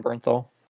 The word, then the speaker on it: Bernthal: John Bernthal.